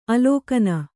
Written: ಅಲಕ್ಕನೆ

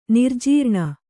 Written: ನಿರ್ಜೀರ್ಣ

